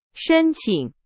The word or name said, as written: 申请